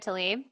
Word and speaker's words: Tlaib: Oh, Rashida Tlaib.